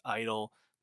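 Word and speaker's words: (G)I-DLE: Yes, Idol, the G is silent.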